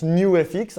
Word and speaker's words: Nux: Alors, on prononce New FX, hein, c'est comme ça, c'est comme ça qu'on le fait.